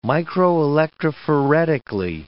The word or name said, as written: microelectrophoretically